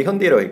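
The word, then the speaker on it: Hyundai: 그러니까 걔네들이 발음할 때 일부러 처음에 현대에서 나갈 때, 썬데이 먼데이 현대이라고 했거든요. 아, 그렇게 광고를 했어요, 진짜로. 썬데이 먼데이 현대이 하니까 영어인지 아닌지 몰라요.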